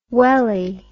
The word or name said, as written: weli